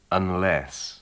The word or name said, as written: unless